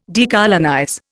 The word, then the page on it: decolonize: Indian